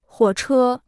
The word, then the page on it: 火车: huǒ chē